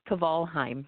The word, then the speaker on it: Kvalheim: It's Kvalheim, yes.